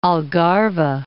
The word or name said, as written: Algarve